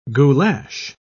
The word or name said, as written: goulash